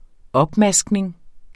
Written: opmaskning

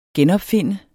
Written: genopfinde

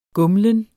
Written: gumlen

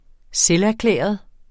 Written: selverklæret

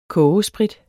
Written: kogesprit

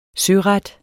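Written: søret

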